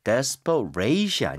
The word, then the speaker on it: desperate: 오, 지금 발음 들으니까 desperate이 아닙니다. – 네. – Desperate. 이렇게 해서 ate가 동사 빼고는 다 어에 가깝게 발음하는 게 맞죠, 그렇죠? – 네, 맞아요.